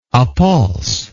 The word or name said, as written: appulsively